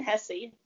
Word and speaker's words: Hesse: And I'm Elinne Hesse. – Okay, that's how you say your name. Now I know. I definitely did not know it was Hesse.